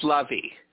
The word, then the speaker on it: Lavie: But my first name is pronounced Lavie.